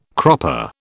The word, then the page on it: cropper: British English